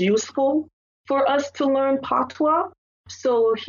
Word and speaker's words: Patois: Do you also speak Patois? Am I saying that right? – Yes, Jamaican Patois, yes.